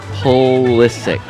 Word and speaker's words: Pulisic: The last name is pronounced Pulisic. – Pulisic. – Pulisic. – Okay, I can get that. – So lots of people have pointed out it's not the itch.